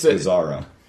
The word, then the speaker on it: Gazzara: It's not that hard to pronounce. It's Gazzara. – Gazzara.